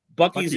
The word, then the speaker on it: Buc-ee's: Bussy, but, am I saying that correctly? Yeah, dude, Buc-ee's, Buc-ee's is the best.